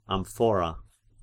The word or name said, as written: amphora